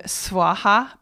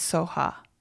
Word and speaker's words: Swaha: And again, I mentioned yesterday the Soha is also the word Swaha, but in Tibet or the Buddhists pronounce the word Swaha as Soha.